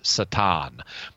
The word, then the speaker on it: Satan: So he's got he's the king, he's got his princes there, and in comes this figure known as Satan, or to pronounce it the Hebrew way in this case, Satan.